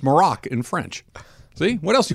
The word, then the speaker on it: Maroc: Uh, Golan's Shahibi says, Hey, just an FYI, Morocco is abbreviated MAR because it's known as Maroc, M A R O C, in French.